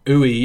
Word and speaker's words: Ooey: Is it Ooey Burger? – Ooey, yeah. It is pronounced like Ooey.